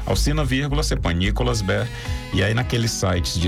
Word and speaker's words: Behr: @ Nicolas Bé, tudo junto. Bé se escreve B-E-H-R.